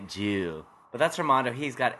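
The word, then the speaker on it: Joule: Well, I was going to say Jolie Dallas, 3-night stay at the Jolie Dallas Hotel. And, of course, the Dallas Convention and Visitor Center. Oh, he said Jewel. – Oh, he said that's pronounced Jewel.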